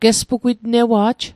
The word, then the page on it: gespugwitnewa'j: Mi'gmaq